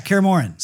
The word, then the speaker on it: Kaer Morhen: His name is Doug Cockle and brings with him now, correct me if I mispronounce this. Kaer Morhen. – Yeah, Kaer Morhen.